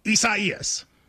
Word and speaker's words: Isaias: A lot of people, prayers to everybody involved, but it is Isaias. – Is that how you say it, though? – You do.